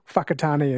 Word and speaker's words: Whakatane: I think the WH is pronounced F, like Whakatane is WH, Whanganui, WH, instead of F.